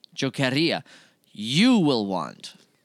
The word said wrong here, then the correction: querría: The difference, it's going to be only in the R. That's what's important, the pronunciation. So, quería, it's in the past. Querría, it's in the future.